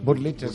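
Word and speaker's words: Wurlitzer: Pero sí, o sea, justo ahora venimos a hacer la Burlitzer, creo que se pronuncia Burlitzer. Sí, Burlitzer.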